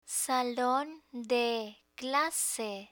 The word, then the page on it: salon de clase: spanish